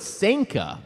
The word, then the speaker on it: Sanka: I didn't know how to say Sanka, so I had to look it up. I thought it was like manga, but no, it's Sanka. Manka like Sanka.